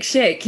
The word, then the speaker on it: Schick: The Czechs, I think, have been fantastic, shtick. Have I said his name right? – Patrick Schick.